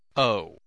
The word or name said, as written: owe